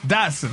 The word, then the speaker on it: dachshund: How do you say that? Dax Shaw? D-A-C-H-S-H-U-N-D. – Dotson.